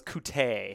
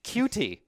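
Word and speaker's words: Coutee: I heard the pronunciation was Kute. – It's not. – It's not? – I've watched every one of his receptions. It's Cutie.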